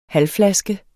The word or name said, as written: halvflaske